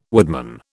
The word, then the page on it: woodman: British English